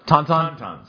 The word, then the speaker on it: Tauntaun: Tauntaun? – Tauntaun.